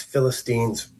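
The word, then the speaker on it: Philistine (فلسطين): And they change the name Judea to Palestina, which means Philistines. To poke them in the eye, that's what Palestine means. You can hear it in Arabic, which is interesting. They actually pronounce it Philistine in Arabic.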